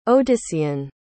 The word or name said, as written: odyssean